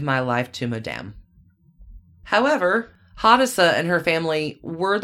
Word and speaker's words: Hadassah: Hadassah? I hope I'm saying that right. H-A-D-A-S-S-A-H? – So, yeah, Hadassah.